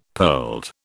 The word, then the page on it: pearled: British English